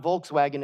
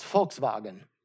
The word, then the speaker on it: Volkswagen: How many of you know that it's not Volkswagen, it's Volkswagen? Okay, the W is a V.